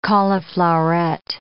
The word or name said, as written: caulifloweret